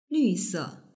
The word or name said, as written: lvse